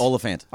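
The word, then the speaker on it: Olyphant: Oliphant.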